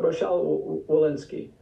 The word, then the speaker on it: Rochelle Walensky: Wachel Walensky, what's her name? How do you say that? – Ro- Ro- Rochelle Walensky.